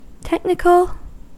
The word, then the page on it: technical: American English